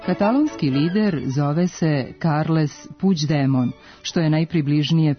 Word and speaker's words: Puigdemont: G u kombinaciji glasova IGD od izvornog Puigdemont, kako se na katalonskom piše ovo prezime, izgovara se približno našem dž, ispred kojeg postoji prizvuk glasa i, koji se nedovoljno jasno čuje i lepi se na afrikatu, pa ga nije potrebno prenositi, a d još uvek nije postalo sporno.